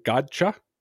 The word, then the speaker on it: Godchaux: One thing that Henry Anderson and Godchaux doesn't do, Godchaux, that's how you say it, right? Godchaux.